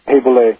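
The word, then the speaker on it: Boulé: When I was reading the released FBI files on him, I found out about a secret society called, it was spelled B-O-U-L-E, I think it's pronounced Du Bois. – Boulay.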